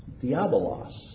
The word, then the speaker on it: diabolos: This term is pronounced diabolos.